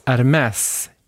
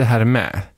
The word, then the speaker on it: Hermès: Han menade, hallå, väskorna uttalas Hermès, inte Hermé.